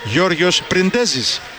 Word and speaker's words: Γιώργος Πριντέζης: Ο κύριος Γιώργος Πριντέζης, αυτή η τεράστια παιχτάρα και προσωπικότητα, είχε πει Γιώργος Πριντέζης.